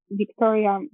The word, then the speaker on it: Wiktoria: But I'm mainly quite active on Instagram, and my handle is @ Dr. Dr. Wiktoria Maria, spelled with WIK. So my name is actually spelled WIKTORIA, and then Maria. So @ Dr. Wiktoria Maria.